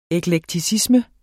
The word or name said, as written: eklekticisme